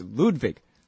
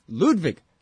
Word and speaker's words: Ludwig: Well, I've said it wrong all along until Deist told me. No, it's Ludwig. Ludwig.